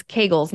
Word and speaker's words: Kegels: Where your Kegels rather, you know, it's Kegels where you just, it's Kegels, not Kegels. It rhymes with bagels.